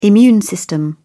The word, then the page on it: immune system: British English